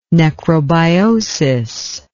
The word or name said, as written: necrobiosis